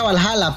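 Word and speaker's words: VA-11 Hall-A: Bueno, se pronuncia Valhalla, pero se escribe V-A-H-1-1-A.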